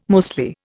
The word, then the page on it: mostly: Indian